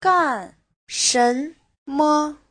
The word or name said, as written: gan4shen2me5